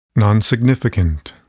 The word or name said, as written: nonsignificant